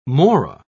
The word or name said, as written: Mora